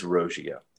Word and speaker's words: Roggio: So I have no problem with the occasional mispronunciation, but it is Roggio.